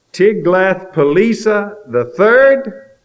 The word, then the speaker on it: Tiglath-Pileser: Tiglath-Pileser III See, it almost rhymes with Elisa. He was also called Pul. That's P-U-L.